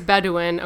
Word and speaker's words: Bedouin: It is Bedouin, according to Bedouin. – Wikipedia. – Bedouins. – Bedouin. – My mistake.